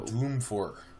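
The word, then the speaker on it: Dwumfour: The Michigan guys, Carlo Kemp, and I can never say his name. Michael Wumafor? – Michael Doomfor. – Yeah, I know. Doomfor. I don't know why I wanted to say Wumafor.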